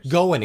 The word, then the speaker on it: Goin': Those are Donald Duck Goin' Quackers and Duck Dodgers, starring Daffy Duck. – Are you sure it's not Goin' Quackers? – Goin', excuse me, you're right. Goin', like going my way.